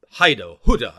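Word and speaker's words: Hyder: And then Welsh Water, it was called Huda, I think it's correctly pronounced. Huda. I always call it Huda, because it's H-Y-D-E-R.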